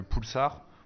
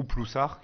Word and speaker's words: Ploussard: Et après, c'est deux cépages purement jurassiens, tu as le poulsard ou ploussard, qui est le même en fonction du village dans lequel tu es, tu as le droit de le prononcer différemment. comme Ça. Plouplou de Ploussard pour pupilles hein, et Poulsard à Arbois.